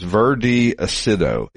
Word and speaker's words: Verde Acido: It's Verde Acido, A-C-I-D-O, and it's this limey green color with white interior, 89,000 kilometers, 2-liter twin spark inline four.